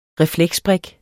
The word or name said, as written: refleksbrik